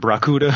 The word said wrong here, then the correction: Bakuda: Bakuda, You got it. You got it.